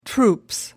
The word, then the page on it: troops: British English